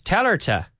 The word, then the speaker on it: tell her to: And today we're going to practice telerta. Telerta. Now, I could say tell him to. Tell him to. And the female version, tell her to.